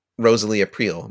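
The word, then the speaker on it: Rosalie Aprile: And, you know, Ralph's official girlfriend is Rosalie April. Am I getting the name right? – Yep.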